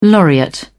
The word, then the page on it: laureate: British English